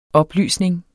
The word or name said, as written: oplysning